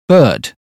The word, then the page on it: bird: British English